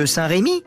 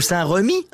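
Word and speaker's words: Saint-Remi: On dit même que Saint-Rémy ou Saint-Remy, hein, les Rémois eux-mêmes ne sont pas d'accord sur comment il faut prononcer, donc moi je dis les deux, comme ça tout le monde est content. Saint-Rémy, Saint-Remy, eh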